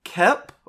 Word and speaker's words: Koepp: So this is written and directed by David Koepp, I believe is how it's pronounced, who is a longtime Hollywood staple.